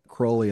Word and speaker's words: Crowley: Oh, Crowley. Yes, Crowley. Sorry. Rhymes with holy.